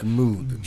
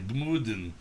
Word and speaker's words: Gmunden: Gmunden. – Gmunden, Austria.